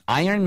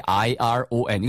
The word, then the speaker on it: iron: When it's not easy, then have an iron man do it. 그쵸? – Good idea. – 네, 좋습니다. 그래서 shirt를 다리다 할 때 iron. 이거 절대 iron 아니에요. – Iron. – 네, 발음이 iron입니다.